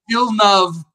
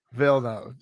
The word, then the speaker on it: Villeneuve: I looked up the pronunciation. Villeneuve. – Villeneuve.